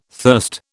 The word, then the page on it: thirst: British English